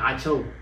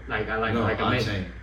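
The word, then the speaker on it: Achane: Ache. Not Acho. Ache.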